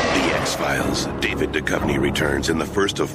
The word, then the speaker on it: David Duchovny: Możecie usłyszeć, jak prawidłowo się to mówi. David Duchovny. Tak to powiedział ten speaker na Fox. On chyba wie.